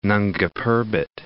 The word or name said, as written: Nanga Parbat